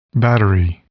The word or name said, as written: battery